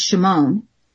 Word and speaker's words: Shimon: The most fascinating one to me is the name Simon, which pronounced in Hebrew is Shimon.